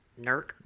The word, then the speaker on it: Newark: As a Pataskla girl, it's pronounced Nurk. – Nurk. It's in Nurk, Ohio.